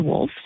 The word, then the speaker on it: Wolfe: And interesting enough, our last name is Wolf, spelt with W-O-L-F-E.